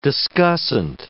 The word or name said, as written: discussant